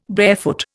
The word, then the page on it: barefoot: Indian